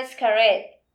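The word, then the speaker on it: Xcaret: a Escaret. Así se pronuncia, ¿no? – Sí.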